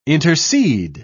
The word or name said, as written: intercede